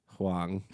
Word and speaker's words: Huang: Huang. That's what's wrong. If you add another syllable, we were trying to decide how much H sound to put into Erica's name.